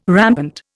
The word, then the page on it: rampant: Indian